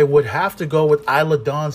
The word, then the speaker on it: Isla Dawn: Uh, the first matchup is going to consist of Alba Fire to take on think her name is Isla Dawn.